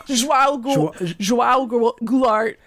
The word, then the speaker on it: João Goulart: João Goulart. Wrong? – João Goulart.